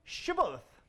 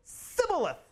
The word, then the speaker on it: Shibboleth: And he would say, Sibboleth. For he could not pronounce it right. And then they would take him and kill him at the fords of the Jordan. And there fell at that time 42,000 Ephraimites. They couldn't pronounce the sh. They said th, th, th.